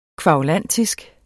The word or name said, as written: kværulantisk